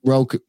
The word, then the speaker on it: Roku: Roku.